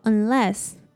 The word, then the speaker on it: unless: 这里的 u 我们要发 a 的音念作 un 那后面的 e 则是发 a 一样把重音放在第二个音节念作 unless 那我们再来放慢速度念一次句子咯 You're not making savings a priority Whether you're saving for a house For that new car, or simply for your retirement, you will never reach your goal unless you make savings a priority.